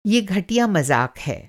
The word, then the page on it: घटिया: گھٹیا